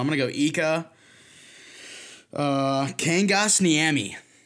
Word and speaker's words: Kangasniemi: Because I could see how you can pronounce it Kangas. It's more Kangas because it's how the Euros pronounce it.